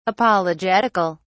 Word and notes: apologetical: British English

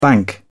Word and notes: bank: British English

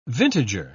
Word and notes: vintager: British English

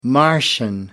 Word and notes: martian: American English

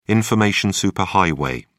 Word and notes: information superhighway: British English

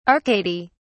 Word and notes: arcady: British English

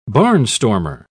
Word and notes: barnstormer: British English

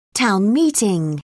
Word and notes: town meeting: British English

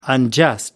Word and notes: unjust: American English

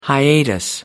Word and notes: hiatus: American English

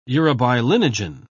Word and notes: urobilinogen: British English

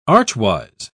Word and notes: archwise: British English

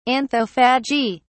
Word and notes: anthophagy: British English

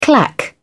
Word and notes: clack: British English

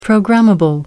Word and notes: programmable: American English

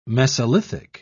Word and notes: mesolithic: British English

